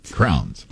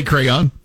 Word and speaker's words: crayon: Crowns. Yeah, crowns. You can say it, like the thing you put on your head. Crowns. – That's not what it is. How do you say that word? – Yeah, you know, the wax things that you color with. Crowns. Yeah, I say crayon.